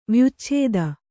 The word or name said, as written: ವ್ಯುಚ್ಛೇದ